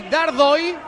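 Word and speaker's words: Dárdai: Fiola, Orban y Dardoy, aunque se escriba Dardoy, pareja de centrales, que son tres, ¿eh?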